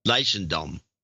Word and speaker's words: Leidschendam: Leidschendam.